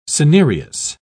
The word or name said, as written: cinereous